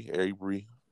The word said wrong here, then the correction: Ahmaud Arbery: Oh, Ahmaud Arbery.